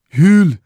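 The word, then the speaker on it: jul: Hul. Se escreve com J, um U e um L, mas lê-se Yule que os J's não se lêem em norueguês, está vendo?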